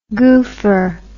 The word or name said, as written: goofer